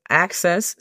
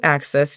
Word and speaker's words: access: Entonces, access significa acceso. Imagínate que estás haciendo la pronunciación de la palabra haces en español. ¿Qué haces? Haces. Simplemente le agregas la K. Access. Igual para cuando decimos to access, simplemente añadimos T-U, to y después access.